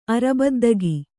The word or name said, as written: ಅರಬದ್ದಗಿ